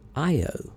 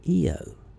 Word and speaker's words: Io: Some call one of Jupiter's moons Io, and some call it Eo. Now, I believe Eo is a more correct pronunciation, but I want you to feel free to pronounce it any way you want to. If you like Io, that's fine with me. But I'm sticking with Eo.